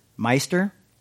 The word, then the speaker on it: Meister: Is it Mr. Eckhart? – Meister.